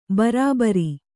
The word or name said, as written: ಭರಭಾರಿ